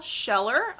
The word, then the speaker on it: Scheller: April Scheller. April is spelled like the month. Scheller is S-C-H-E-L-L-E-R. – Okay, so I have A-P-R-I-L, last name S-C-H-E-L-L-E-R.